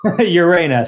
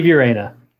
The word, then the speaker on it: Urena: Better say your last name. I'm going to mess it up again. – Urena. Steve Urena. – Okay, good. I look at it and I just want to say Uranus for some reason.